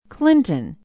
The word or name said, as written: clinton